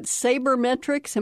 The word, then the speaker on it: sabermetrics: Are you talking about sabermetrics? Am I pronouncing it correctly? – That's right, yes.